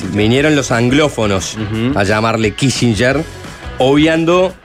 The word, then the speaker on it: Kissinger: Ah, bueno, sí, si querés acentuarlo un poquito mejor, Kissinger. Kissinger.